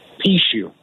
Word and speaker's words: Pucheu: In Mississippi, your last name is spelled P-U-C-H-E-U. How do they pronounce that in Mississippi? – Pishu.